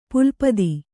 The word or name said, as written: ಪುಲ್ಪದಿ